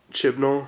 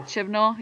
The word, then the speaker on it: Chibnall: How do you say his name? – Chibnall. – Chibnall, yeah.